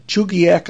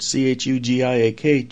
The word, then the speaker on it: Chugiak: On 1160, an application has been tendered in Chugiak, Alaska, I think is how you pronounce it, Chugiak, Chugiak, 9,500 watts during the day, 410 watts at night, non-directional.